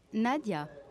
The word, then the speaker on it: Nadia: Nadia. – How do you spell that? – N-A-D-I-A.